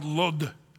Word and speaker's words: Lod: Now, I just want to say, this is kind of fun here, because this place, Lydda, it's also in modern day, it's called Lod, L-O-D. That's how they say it in Israel, Lod.